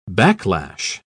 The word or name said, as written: backlash